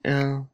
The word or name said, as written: el